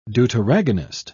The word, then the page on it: deuteragonist: British English